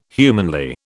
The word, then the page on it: humanly: British English